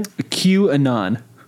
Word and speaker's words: QAnon: How do you say it again? – QAnon. – QAnon, okay.